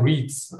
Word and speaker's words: REITs: Deswegen haben wir uns als Spezialthema hier die Rates rausgesucht. Ich hoffe, ich habe es richtig ausgesprochen. – Äh, ich glaube, die Amerikaner sagen REITs, also REITs.